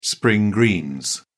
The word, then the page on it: spring greens: British English